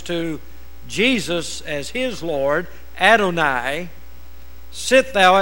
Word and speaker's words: Adonai: And that primarily has to do with the Father, said unto my Son, and that is Adonai. A-D-O-N-A-I is the way it's spelled, Adonai.